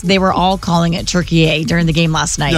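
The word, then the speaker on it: Türkiye: And I was surprised to see this morning that Turkey, or Turkey A, the way that I guess it's pronounced Turkey A, won 3-2, blocking us from completing that perfect World Cup start. We still advance. The knockout match is scheduled for Wednesday. I believe we play Bosnia. – Okay. I don't know why it just strikes me as funny that suddenly I'm hearing all the sportscasters say, Turkey A! When, like, in English, we pronounce it Turkey.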